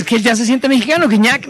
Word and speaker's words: Gignac: Ah, así se pronuncia, ¿verdad? – Gignac. Que no Gignac.